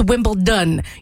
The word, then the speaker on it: Wimbledon: Everyone in here does it perfectly, but for those of you out there, I think a lot of people know that Brits pronounce their T's, so they'll say Wimbledon. It's a D. It's Wimbledon. You're saying it correctly if you say it with a D. If you say it with a T, you're saying it wrong.